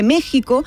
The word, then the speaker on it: México: Y desde entonces, en sus diccionarios, deja claro que, aunque se pronuncie México, escribirlo con J está desaconsejado.